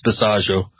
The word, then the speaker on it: Bisaggio: It rhymes with Chicago. It's Bisaggio.